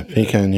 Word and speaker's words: Paykan: Yeah, Paykan.